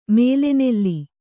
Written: ಮೇಲೆನೆಲ್ಲಿ

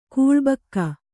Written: ಕೂಳಬಾಕ

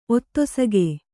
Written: ಒತ್ತೊಸಗೆ

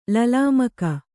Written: ಲಲಾಮಕ

